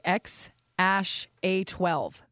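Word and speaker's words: X Æ A-12: So, I mean, it's just X, the letter X. And then the AE is like pronounced Ash. yeah. And then A12 is my contribution. – Okay, X Ash A12.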